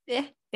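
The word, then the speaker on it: Ea: Okay, so he was also considered a representative of the water god. I say A, it's spelled E-A. – Eh.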